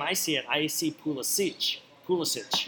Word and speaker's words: Pulisic: Biggest thing coming out of that match and last week's podcast is that we don't know how to pronounce Christian Pulisic's name.